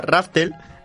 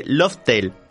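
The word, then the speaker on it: Laugh Tale: Y nombraron a la isla Raftel. Realmente es una pronunciación japonesa de Loftel.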